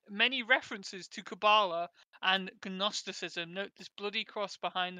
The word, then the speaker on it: Gnosticism: You don't have to pronounce the G. Like, oh, Gnosticism.